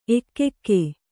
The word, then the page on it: ಏಕೈಕ: ēkaika